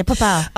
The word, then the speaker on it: Papua: How do you say Papua? – Yeah, Papua.